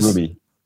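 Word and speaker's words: Roumie: Rumi. – Kumi. Did I say it right? – I thought it was Rumi. – Let me look him up again here. It's on my page. Yeah, it is Rumi.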